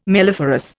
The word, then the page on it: melliferous: Indian